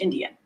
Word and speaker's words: NDN: So in that instance, he uses NDN, uppercase, which is just a common slang, I think, used for and pronounced Indian.